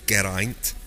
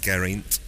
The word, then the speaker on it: Geraint: We've said, it's been pointed out to us again by the pronunciation police, it's Geraint, pronouncing every letter, not Geraint.